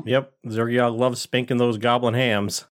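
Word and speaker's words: Xergiok: Is that how you say his name? – Zergiok. Yep.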